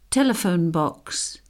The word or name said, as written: telephone box